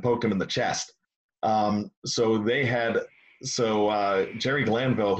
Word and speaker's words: Jerry Glanville: You know why I'm calling him Gary Granview and not Jerry Glanville? – That was how the Three River Stadium PA announcer pronounced his name back when he first started in it was 87, 86.